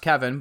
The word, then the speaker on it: Kevan: It's pronounced Kevin, but it's spelled Kevan Miller.